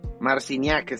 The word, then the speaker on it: Marciniak: Marciniak, como si chingado se pronuncia. Marciniak, exactamente.